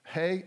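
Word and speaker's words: hey (ה): Hey, H-E-Y, hey, it's the 5th letter.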